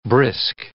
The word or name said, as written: brisk